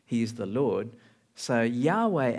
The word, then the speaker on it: Yahweh: In the original, it actually spells something like YHWH. I don't know how you pronounce that, you know, but we've anglicized it to Yahweh. You might have heard that name, right? Yahweh, which is God's personal name.